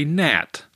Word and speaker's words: gnat: For example, if I said tang, T-A-N-G, you would say gnat, which starts with a silent G. So here you go. Number one is bonk, B-O-N-K.